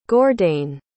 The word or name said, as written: gordain